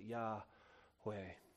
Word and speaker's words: Yahweh: And this is the best approximation of how to pronounce it, Yahweh.